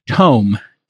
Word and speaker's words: Tome: He is the CEO and co-founder of Tome, T-O-M-E.